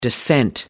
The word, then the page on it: dissent: American English